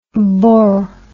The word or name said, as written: bor